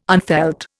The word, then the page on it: unfelt: Indian